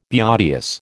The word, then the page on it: beauteous: American English